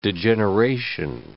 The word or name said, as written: degeneration